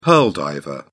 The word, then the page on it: pearl diver: British English